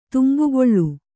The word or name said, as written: ತುಂಬುಗೊಳ್ಳು